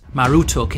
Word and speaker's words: Marduk: However, it was actually pronounced Marutuk in Babylonian.